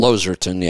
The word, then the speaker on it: Losartan: Is that how you pronounce that one? – Losartan, yeah. Losartan.